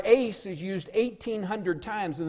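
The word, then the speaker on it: eis: And the Greek word in Acts 2:38 for this little word for is pronounced ace.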